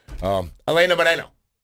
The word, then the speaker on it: Helena Moreno: Out of all the New Orleans City Council members, my favorite is Helena Moreno. Hold on. I should say that properly. I shouldn't say that like a gringo. Helena Moreno.